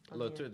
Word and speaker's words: LaTorre: LaTorre, that's my last name, yes.